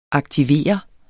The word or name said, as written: aktivere